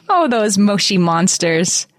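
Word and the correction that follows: Moshi Monsters: They're actually like moshy monsters. Bubbles. We're supposed to, we should know better to say it correctly.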